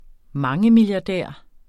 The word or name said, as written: mangemilliardær